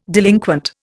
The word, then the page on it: delinquent: Indian